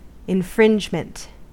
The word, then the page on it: infringement: American English